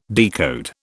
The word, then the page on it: decode: British English